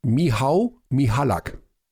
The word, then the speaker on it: Michał: Michal Michalak. – Nein. Alexander Supertramp sagt, der Vorname wird Michał ausgesprochen. Michał Michalak.